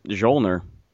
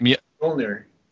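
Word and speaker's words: Mjölnir: He pronounces it Mjolnir. – That's the correct. – Mjolnir. – Mjolnir. Like the Halo armor.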